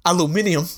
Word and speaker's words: aluminium: It's not aluminum, but... – Definitely not goddamn aluminum. Made it out of aluminum. – Or as the British say, aluminium.